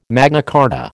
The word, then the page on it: Magna Carta: American English